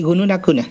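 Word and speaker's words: Günün a küna: Es docente, profesor de lengua originaria del Cuy, de la lengua Gunun Acuna. Decime si lo estoy pronunciando bien, por favor. – Sí, Gunun Acuna, es el pueblo nuestro, y Gunun Ayajich, el idioma.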